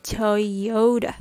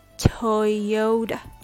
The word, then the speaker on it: Toyota: The first syllable is toy. Toy. Toy. Like what a child likes to play with then oh, oh, oh. Toyo, toyo. The last syllable is d, d, d. It's a very relaxed sound with what's called a flap T sound. D. Toyota. Toyota. Toyota. Make sure the stress is on the second syllable, yo.